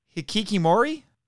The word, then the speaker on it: hikikomori: again, make sure I'm saying this right, the Hikikomori? – Yes.